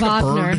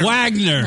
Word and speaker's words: Wagner: Wagner. – No, Wagner. – How is that fucking Wagner? – If it's Wagner, spell it with a fucking V. – It's just the Germanic pronunciation.